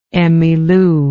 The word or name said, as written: Emmylou